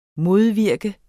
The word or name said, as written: modvirke